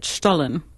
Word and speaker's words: Stollen: My grandfather pronounced it Stollen. – Yes, very good.